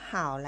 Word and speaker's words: 好: ำนี้ค่ะคุ้นๆอยู่ไหมคะก็คือคำว่าเห่านั่นเองนะคะเราเคยเจอคำนี้มาแล้วตั้งแต่บทที่ 1 ค่ะคำนี้นะคะนอกจากจะออกเสียงว่าเห่า